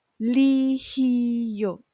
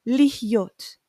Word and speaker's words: להיות: It's actually, we're kind of losing the her because originally we're supposed to pronounce it lihiyot. But when we sing it, the her kind of lost in the process. Lihyot, lihyot, lihyot, lihyot am chofshi.